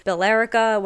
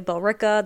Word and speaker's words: Billerica: I mean, people from out of town say Billerica when it's really Billerica. They say Haverhill when it's Haverhill.